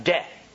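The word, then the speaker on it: de (δέ): And then he uses a tiny little word in the Greek, and I can pronounce this one right off the bat, "de."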